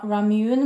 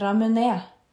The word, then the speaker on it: ramune: Den som heter Eller jeg sa ramune, men det er feil. Det er ramune, heter den.